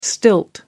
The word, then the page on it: stilt: American English